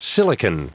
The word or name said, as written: silicon